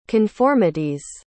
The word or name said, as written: conformities